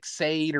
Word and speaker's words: Sade: But it's like, what is it, that musician, Sade or something like that, and her name is spelled S-A-D-E, like Sade or Sadie, but it's Sade.